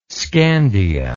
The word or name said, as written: Scandia